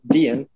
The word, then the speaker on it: Dehen: And we pronounce it Dehen. It's spelled D-E-H-E-N. I've heard all sorts of pronunciations, but we say Dehen.